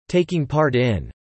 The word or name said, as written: taking part in